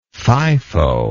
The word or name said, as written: FIFO